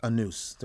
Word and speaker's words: Anous: Now, if you spell out his name and pronounce it in English, it is actually Luc Anous, okay? But it's pronounced Anous.